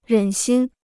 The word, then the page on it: 忍心: rěn xīn